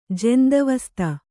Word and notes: ಜೆಂದ್‍ಅವಸ್ತ: kannada

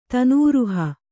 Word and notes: ತನೂರುಹ: tanūruha

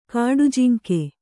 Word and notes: ಖಾಡಾಜಂಗಿ: khāḍājaŋgi

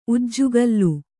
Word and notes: ಉಜ್ಜುಗೋಲು: ujjugōlu

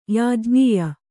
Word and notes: ಯಾಜ್ಞೀಯ: kannada

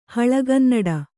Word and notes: ಹಳೆಗನ್ನಡ: kannada; haḷegannaḍa

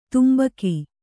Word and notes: ತಂಬಾಕು: kannada

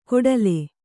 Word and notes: ಕೊಡಲೆ: kannada

